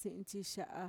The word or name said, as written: si'intxixa'a